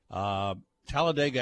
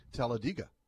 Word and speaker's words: Talladega: Talladega.